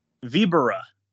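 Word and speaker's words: Víbora: Damn it, I'm saying that wrong. – Vibura.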